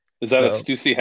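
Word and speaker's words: Stüssy: So that's why I'm just kind of giving it a shout out because they did send me do you say it's Stussy? – Stussy. – I wore the shirts in high school and I never knew how to say it.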